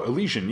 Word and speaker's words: Elysian: Oh, Elysian, yeah. Elysian, yeah. is that it? Is that how it's pronounced? – I mm-hmm.